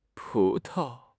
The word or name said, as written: pu2 tao5